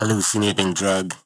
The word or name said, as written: Hallucinating Drug